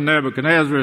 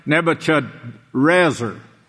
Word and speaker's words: Nebuchadrezzar: It's not even Nebuchadnezzar. It's Nebuchadrezzar. And you cannot pronounce that in any other way except Hebrew.